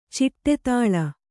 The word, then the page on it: ಚಿಟ್ಟೆತಾಳ: ciṭṭe tāḷa